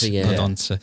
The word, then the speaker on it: Podence: Is it Podent or Podence? – I think Podence. Sounds far more Portuguese. – Podence.